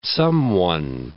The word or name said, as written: someone